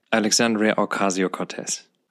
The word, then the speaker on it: Alexandria Ocasio-Cortez: Ah ja, Alexandria Ocasio-Cortez.